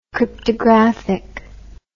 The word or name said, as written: cryptographically